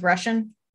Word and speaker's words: Rushan: What, his last name is Russian? – Not spelled like Russian, though. – Oh, like R-U-S-H-A-N.